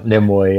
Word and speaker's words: Nimue: And I will guide you to a witch, Vivian Nimue. Is that how you say it again? Sorry. – Yeah, Nimue, yeah.